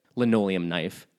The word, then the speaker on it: Linoleum Knife: I listen to their, I love Linoleum Life. I highly recommend you listen to it. Linoleum Knife. There, I said it correctly.